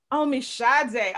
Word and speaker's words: Omisade: Omisade.